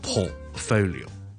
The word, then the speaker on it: portfolio: 佢因为佢成日听到人读咧 portfolio，正确发音咧当然系 portfolio， portfolio。 portfolio port 係一個 o 音啦，o o po po po，跟住有個 t 喺度。但係我 t 咧好多時候就唔需要咧係 t 出嚟嘅，我就咁讀 po，跟住急停就可以啦。